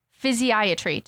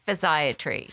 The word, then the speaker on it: Physiatry: Did I say that correct? – No, but that's okay. Oh. No one does. – Okay. How do I say it then? – Physiatry. – Physiatry. Okay. I'll remember that.